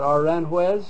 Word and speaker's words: Aranjuez: When I was doing the Aranjuez, is that how you pronounce it, Aranjuez? – Yes.